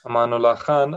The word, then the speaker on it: Amanullah Khan: How did Amula Khan come, sorry if I said his name wrong, but how did he come to power? – Amanullah Khan, how did he come to power?